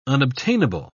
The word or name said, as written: unobtainable